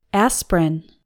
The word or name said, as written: aspirin